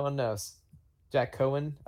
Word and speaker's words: Coan: Well, it's Cohen, like C-O-A-N, but I've heard a lot of like hard pronunciation of the O to the A.